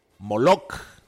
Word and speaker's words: Moloch: Guillermo, Salomón, Adalberto, Orosia, Moloch, porque tiene acento en la segunda O.